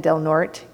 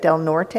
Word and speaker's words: Del Norte: I learned it's Del Norte. It looks like Del Norte, if you know Spanish, but the locals pronounce it Del Norte.